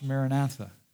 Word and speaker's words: Maranatha: That word is pronounced Maranatha.